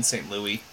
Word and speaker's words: St. Louis: It's Meet Me in St. Louis.